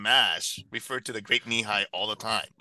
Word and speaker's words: Nehi: I didn't know that it was knee high because it's spelled NEHI, and it's, Nehi sounds very Hawaiian.